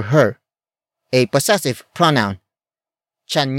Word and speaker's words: tiyan: It is the focus marker that introduces the main topic of this sentence, and that is chan. Chan. Chan. Chan is spelled as T-I-Y-A-N.